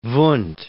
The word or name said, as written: Wundt